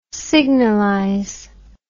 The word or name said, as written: signalise